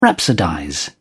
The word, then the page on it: rhapsodize: British English